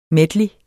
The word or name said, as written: medley